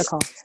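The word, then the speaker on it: Coel: It's spelled different though, isn't it? – Yeah. So the E and the L are like switched the other way around. Yeah. But it's Michaela Cole.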